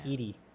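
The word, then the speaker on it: Ncuti: Is Scooty? – Yeah, Scooty Gadwa, yeah. – No, it's pronounced Skeety.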